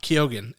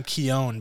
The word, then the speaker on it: Keoghan: Keoghan is how he said his name is pronounced. I pronounced it for the longest time Keown because I read that somewhere, but that's not right. It's Keoghan.